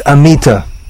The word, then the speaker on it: Amita: But we didn't know how to pronounce names, so just we saw the name written, A-M-I-T-A, and we pronounced it the best we could. And in English pronunciation, you generally stress the penultimate syllable, so it came out Amita.